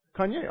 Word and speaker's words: Kanye: Kanye, oh yeah, there we go.